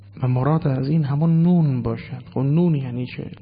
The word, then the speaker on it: ن: اما در این سوره مبارکه بعض از مفسرین هزام آمدن این نون را، ببینید شما در نوشتن نه نوشته شده دیگه، در خواندن میخوانیم نون، یعنی تلفظش نونه، نون نوشتن اون نه.